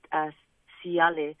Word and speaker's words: taiale: So we learned, and an example would be the word siale, which is a flower, and it's spelled T-A-I-A-L-E. But because there's a vowel I after it, the T turns into an S.